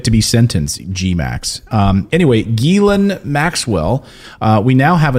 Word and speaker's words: Ghislaine: We knew that there would be another shoe to drop in the Ghislaine Maxwell Jeffrey Epstein saga. And yes, that is how you say her name, according to her brother who published an opinion piece. It is Ghislaine Maxwell.